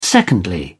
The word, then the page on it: secondly: British English